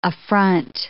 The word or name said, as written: affront